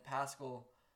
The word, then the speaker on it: Pascal: And then Pascal rhymes with rascal, so Pascal had 3 points and 3 rebounds.